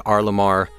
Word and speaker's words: R.LUM.R: R. Lamar is spelled R.L.U.M.R., pronounced R. Lamar.